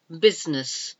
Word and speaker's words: business: Thailand, T-H-A-I-L-A-N-D, Thailand. Next letter is I, and we've got business, business. The business of working, or somebody's business is whatever it is. But it's B-U-S-I-N-E-S-S. You don't pronounce that middle I, business.